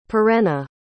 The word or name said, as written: Perenna